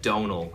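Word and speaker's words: Domhnall: Donal is how I pronounce my name, which is like tonal, but with a D instead of a T.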